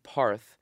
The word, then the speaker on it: Parth: My parents don't call me Parth, they call me Barth. That subtle difference in the way the A is pronounced may seem slight, but Barth feels like a whole other side of me.